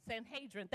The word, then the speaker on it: Sanhedrin: He also was a member of the, there were only 70 members of the Sanhedrin, I'm going to say it wrong, Sanhedrin, thank you, religious court.